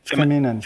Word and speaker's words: féminin: masculine, feminine, feminine, feminine, feminine, Féminin.